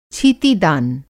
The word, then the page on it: छीती-छान: Hindi